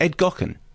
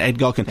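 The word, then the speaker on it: Gaughan: Is it Ed Gochen? – Is it Ed Gochen? – Well, it's written as in Gochen, but it's G-A-U-G-H-A-N. How would you say that? – I wouldn't.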